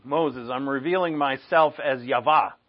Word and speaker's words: Yahweh: Yahweh is really the pronunciation.